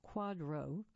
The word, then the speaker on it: QDRO: A QDRO, pronounced quadro, is a qualified domestic relations order, a legal document that accompanies a divorce decree, like an order for child support.